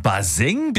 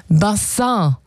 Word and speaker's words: Bassin: Und naja, die Aussprache, die hat ein bisschen gehapert. Also statt Bassin wurde es irgendwann Baseng.